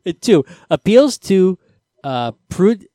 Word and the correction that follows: prurient: What am I saying? I'm saying this wrong again. Prurient. – No D.